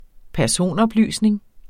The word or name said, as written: personoplysning